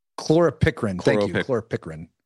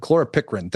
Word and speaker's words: chloropicrin: particularly perchlorin. Did I pronounce that right? – Chloropicrin. – I always mess it up. Chloropicrin. Thank you very much.